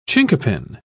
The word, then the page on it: chinquapin: British English